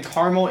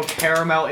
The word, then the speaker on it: Carmel: My grandma lives in Carmel, Indiana. They don't go Caramel, Indiana.